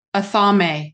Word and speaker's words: athame: Athame.